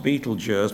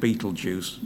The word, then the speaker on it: Betelgeuse: It's officially pronounced Betelgeuse, but everybody calls it Betelgeuse. That sounds rather more fun.